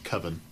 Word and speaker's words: Coven: When you won't even look him in the eye, he's like, well, it's pronounced Coven. – Well, it was pronounced Coven. Nah, man. Maybe. Coven's too much like oven, man.